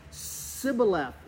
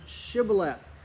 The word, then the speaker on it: Shibboleth: Well, the Ephraimites had a little bit of a speech impediment, and they couldn't say the SH. They said Sibboleth instead of Shibboleth.